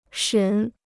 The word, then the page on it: 审: shěn